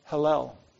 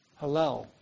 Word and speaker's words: Hallel: Then the first part of the Hallel, which is Psalm 113 to 118, was sung. Hallel. What do you suppose that means? H-A-L-L-E-L. I think that's how you pronounce it. Hallel. Think of one of the songs we sang tonight. H-A-L-L-E-L.